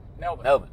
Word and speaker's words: Melbourne: People that are in Melbourne, don't forget Melbourne. Is that how they say it? – Melbourne. Yep.